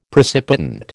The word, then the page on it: precipitant: American English